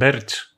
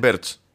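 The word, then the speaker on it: Burch: Birch. Πώ διαβάζεται. Birch. Birch. Birch. Ναι.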